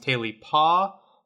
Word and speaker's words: Tailypo: Sometimes it is called Tailybones, sometimes Tailypaw, but the common pronunciation is Tailypo.